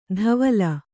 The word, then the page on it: ಧವಲ: dhavala